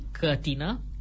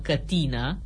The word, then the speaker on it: cătină: Ați pronunțat și voi, ca și mine, numele acelui fruct minune pentru întărirea imunității, cătină? Greșit. Mărturie târzie pentru mine stă Dexul, care spune că există două specii de arbuști spinoși care se cheamă cătină, cu accentul pe i-ul din silaba a doua.